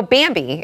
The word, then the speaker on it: Bambee: So Bambi, it's spelled B-A-M-B-E-E, was created specifically for small businesses like yours.